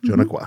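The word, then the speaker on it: Dzunukwa: And the reason I ask this is because, am I pronouncing this right? Jonaqua? – Yeah. Jonaqua? – Jonaqua. Mm-hmm. Female Sasquatch.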